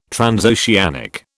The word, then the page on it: transoceanic: British English